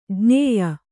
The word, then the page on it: ಜ್ಞೇಯ: jñēya